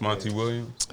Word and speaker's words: Monty Williams: Monty Phillips, Monty Williams. – Monty Williams, I said it wrong.